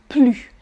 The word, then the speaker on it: plus: What I can say, what is the easiest thing to say is that in negative sentences, you don't pronounce the S, the final S. Plus.